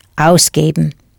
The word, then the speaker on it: ausgeben: Here, to spend it clearly refers to money, so you will use natürlich ausgeben. Hear the accent on the first syllable?